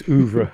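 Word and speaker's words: oeuvre: Do you pronounce it Ouvre? – Yes, Ouvre. – Ouvre. – I like to call it Ouvre.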